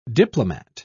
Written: diplomat

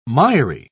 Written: miry

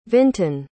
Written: vinton

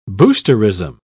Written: boosterism

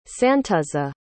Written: santuzza

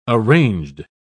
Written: arranged

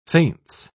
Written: faints

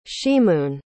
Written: ximun

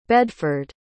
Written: bedford

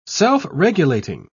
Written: self-regulating

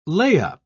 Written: lay-up

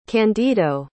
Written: candido

